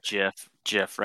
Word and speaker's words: GIF: Ralph really loves that GIF right there of the old lady pouring. – GIF? – Yes, that's how it's pronounced.